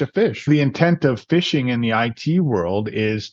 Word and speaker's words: phishing: Phishing, which when you first hear it, it doesn't sound the way it's spelled in IT, which is P-H-I-S-H-I-N-G. Phishing, which is part of spoofing, etc.